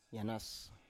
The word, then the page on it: yanas: Biak